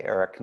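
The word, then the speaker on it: Erik: Google my name and spell it correctly with a K, Eric Nelson, at Creative Differences, and it should take you to our website.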